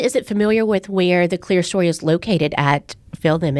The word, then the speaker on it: Clerestory: It is pronounced Clerestory.